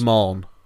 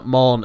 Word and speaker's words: Maugham: No, Maugham as in to mourn something. – Mourn. – Yeah, Maugham. – Okay. – Yeah. – There's no R, though. – It's just how it's pronounced.